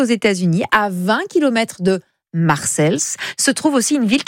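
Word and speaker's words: Marseilles: Si, si, Marseille au pluriel est une ville aux États-Unis, située dans l'Illinois, à une centaine de kilomètres de Chicago. On prononce Marcels plutôt.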